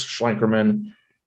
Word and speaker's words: Schlenkerman: So on YouTube, um, it'll just look for my first and last name, Chris Schlenkerman.